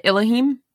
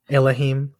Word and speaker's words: Elohim: So how do you say it? Elohim? – Yeah, Elohim. – Elohim. Fucking goddamn.